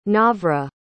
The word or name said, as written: nawra